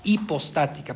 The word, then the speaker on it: hipostática: Entonces, vamos a regresar a este día, a esta frase, la unión hipostática, hipostática, perdón, sin H sin pronunciarlo.